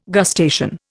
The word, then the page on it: gustation: Indian